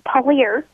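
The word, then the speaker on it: Purlear: It's Pallier.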